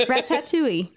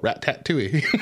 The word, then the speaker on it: Ratatouille: You pronounce tat in there, which... – Ratatouille. – You're all rat-tat-touille. – Ratatouille. – Ratatouille. – It's ratatouille. That's how you say it.